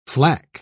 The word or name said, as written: flack